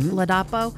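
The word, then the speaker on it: Ladapo: Florida Surgeon General Joseph Latipo. Did I say that right? – You did. – Latipo. – Latipo.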